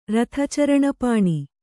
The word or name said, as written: ರಥಚರಣಪಾಣಿ